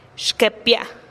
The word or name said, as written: xkepia